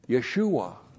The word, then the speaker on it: Yeshua: Yeshua.